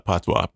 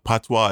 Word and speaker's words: patois: Yeah, they call it patois. Patois is broken English, okay? – Patois is the correct pronunciation. Patois.